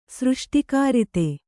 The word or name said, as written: ಸೃಷ್ಟಿಕರ್ತ